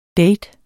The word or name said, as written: date